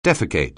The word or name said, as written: defecate